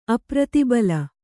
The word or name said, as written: ಅಪ್ರತಿಬಲ